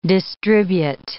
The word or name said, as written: distribute